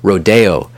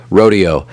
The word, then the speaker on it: Rodeo: Now, some use the Spanish pronunciation, the language from which this word originated, when pronouncing the title of this work, Rodeo. But I prefer the English rendering of it, which is what I believe Copland also favored, Rodeo.